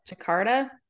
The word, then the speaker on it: Jakarta: It takes place in Jakarta.